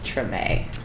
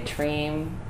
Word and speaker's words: Tremé: For a lot of listeners out there, how do you properly pronounce Tremé? Because there's many... I've heard a lot of people say Treem, you know, tuning in from various states. Can you talk about the pronunciation and where the word comes from? – Treem is definitely and categorically incorrect.